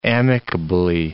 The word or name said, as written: amicably